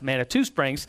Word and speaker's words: Manitou Springs: That we come to find out is pronounced Manitou Springs.